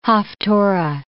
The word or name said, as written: haftarah